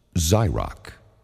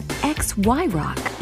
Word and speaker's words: KRXY: It's pronounced Zyrock, and it sounds like dynamite. Y108! Zyrock is the way to. – Uh, excuse me? – Yes. – What exactly is an XY rock? – You mean Zyrock.